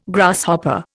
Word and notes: Grasshopper: Indian

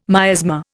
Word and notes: miasma: Indian

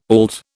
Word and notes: auld: British English

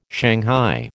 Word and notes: shanghai: American English

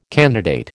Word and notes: candidate: American English

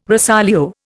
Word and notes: rosolio: Indian

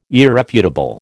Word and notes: irrefutable: American English

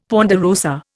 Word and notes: ponderosa: Indian

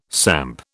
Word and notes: samp: British English

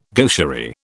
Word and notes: gaucherie: British English

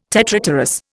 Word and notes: tetrapterous: Indian

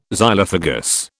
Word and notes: Xylophagous: British English

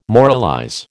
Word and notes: moralize: American English

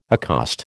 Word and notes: accost: American English

